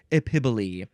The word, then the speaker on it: epiboly: Next is epiboli. Epiboli. This is spelled E-P-I-B-O-L-Y. Epiboli.